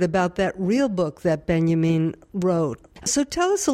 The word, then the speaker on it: Benjamin: and I pronounce it Benjamin because that's probably how most of our listeners will hear it, but Walter Benjamin.